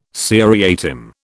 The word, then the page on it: seriatim: British English